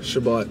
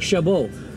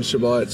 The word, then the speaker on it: Chabot: I would have thought as a French-Canadian and French, it'd be like Chabot. – I know. A lot of people think that for some reason. I don't know. Since I'm born, it's always been Chabot, so I guess we pronounce the T.